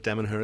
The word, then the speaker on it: Damanhur: How's my pronunciation on that one? – Temple of Damanhur is correct, yes.